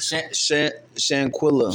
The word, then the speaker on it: Shanquella: Shanquella.